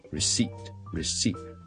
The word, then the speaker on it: receipt: R-E-C-E-I-P-T 个 P 我哋唔需要读嘅，receipt，receipt， may I have the receipt please？